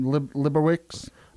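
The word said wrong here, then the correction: Libatique: Labatique. That's what I'm saying.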